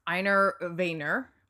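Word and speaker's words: Wegener: Because in the true story, Einar Vayner, although it's spelled very strangely because it's Danish, Einar Vayner...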